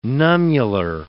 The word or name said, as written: nummular